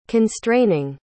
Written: constraining

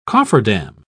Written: cofferdam

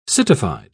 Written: citified

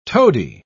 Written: toady

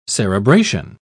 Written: cerebration

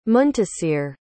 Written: muntasir

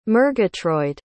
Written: murgatroyd

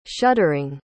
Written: shuddering

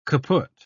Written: kaput

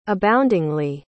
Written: aboundingly

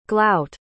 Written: glout

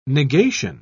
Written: negation

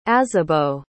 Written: azibo

